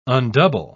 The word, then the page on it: undouble: British English